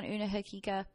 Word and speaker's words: Una Hakika: Today's topic is on Una Hakika. Did I say that right? – You did.